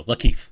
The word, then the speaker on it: Lakeith: His name is Keith, and you just add a L at the beginning. So Lakeith. – Oh, so it's like LeBron, Lakeith.